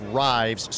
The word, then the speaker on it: Rives: And see, in my case, my name is spelled like it's Rives, so right. I get Rivers, Rivas, oh, yeah. Rivera, Reeves, and everything.